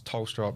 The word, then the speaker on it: Thelstra: but it's like Thomas, like TH, like H is silent, so it's Thelstra.